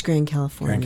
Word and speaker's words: Grand Californian: Grand Californian? I'm saying it wrong. – Yeah. – Yeah. I have to think of like Grand Floridian. – Right. – It's Grand Californian.